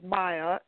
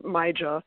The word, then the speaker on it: Maija: Yeah, well, it's actually, my actual name is Myjah, M-A-I-J-A, but it's frequently mispronounced, which is why I go by the pseudonym sort of Myjah as well. So I respond to either one.